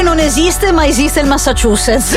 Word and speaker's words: Massachusetts: Rocky con le sue mani ha scritto la pronuncia proprio quella letterale. – Massachusetts. Corretto. Sai che me la potrei posso pronunciarlo in un altro modo, quello che sicuramente mi ricorderò.